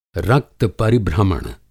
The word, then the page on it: रक्त-परिभ्रमण: Hindi